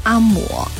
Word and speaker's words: 阿嬷: 因为呢看到这个读音的时候，我也去豆包里面去找了一下，它确实呢是读阿嬷，而且呢它只有这个发音。来看看哦，嬷是读第二声，然后呢这个闽南语和客家话呢是阿嬷。